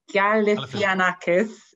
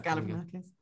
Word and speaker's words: Galifianakis: And Zach, I can never get his name right. Galifianakis, is that right? – Very good, yes, Galifianakis.